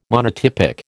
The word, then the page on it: monotypic: American English